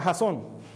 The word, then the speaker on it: jazón: Primero, ¿Qué significa jazón Proverbios 28, 29, 18 dice, por falta de visión es que el pueblo se pierde. Esta palabra hebrea, visión, se pronuncia jazón